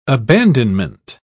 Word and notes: abandonment: British English

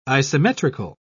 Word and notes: isometrical: British English

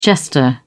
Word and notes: jester: British English

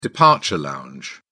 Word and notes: departure lounge: British English

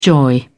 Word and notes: joy: British English

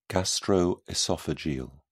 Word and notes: gastroesophageal: British English

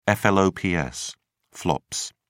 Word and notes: flops: British English

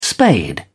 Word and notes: spade: British English

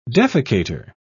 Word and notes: defecator: British English

